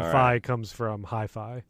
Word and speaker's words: Fi: I say Phi because they have said that it is supposed to be like hi-fi.